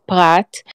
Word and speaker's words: Perat: So that's a prat. It's pronounced prat.